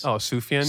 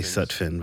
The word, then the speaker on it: Sufjan: Sufjan? – Sufjan. I was going to say Sutphin, but I was like, I know I'm going to say it wrong.